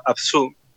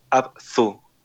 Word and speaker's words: Abzu: Em, pero sí a nivel visual hay otro juego que se llama Abzu, se escribe Abzu, A-B-Z-U.